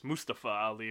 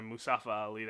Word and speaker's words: Mustafa Ali: It's Mustafa Ali rather than Mustafa Ali that everyone kept saying.